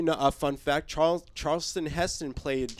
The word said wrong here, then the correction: Charlton: What's his name? Charles? How do you say it? – Charlton. – Charlton Heston.